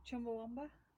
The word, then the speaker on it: Chumbawamba: How do you pronounce it? – Chumbawamba. – Well, everybody says Chumbawamba because we're... because we've got Lancashire accents.